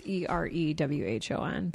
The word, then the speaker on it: Erewhon: It's spelled, nowhere spelled backwards. So it's Erewhon. But my mom was like, Erewhon, Erewhon's the best.